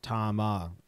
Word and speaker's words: Tama: Uh, the uh, last the last thing from that is uh, just said Tama is named after the wife of the founder. It's Tama in Japanese.